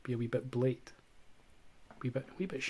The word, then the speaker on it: blate: The Scottish word of the week this week is blate. – Blate. – Blate. – Spell it for us. – B-L-A-T-E.